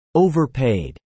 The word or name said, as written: overpaid